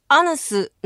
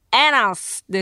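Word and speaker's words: anus: 板橋区の三大都市の制覇さん、ウイカさん、エーノーの名詞形アヌスの正しい発音はエナスです。エナス、エーノー、エナス、エナス、エナス、難しいね。エナスってカタカナでエナスって書いてあるのよ。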